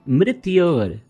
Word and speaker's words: mrityor: Mitror Mushki. – Mitror Mushki. No, it is Mrityor Mukshya. Mrityor. Yes. Mrityor.